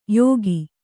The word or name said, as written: ಯೋಗಿ